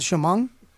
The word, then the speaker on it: Chemung: Is that Chemung? – Yep.